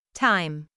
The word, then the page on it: time: taɪm